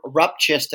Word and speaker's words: Rupchis: Ben Rupchis.